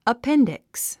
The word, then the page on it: appendix: əpéndiks; アッペンディクス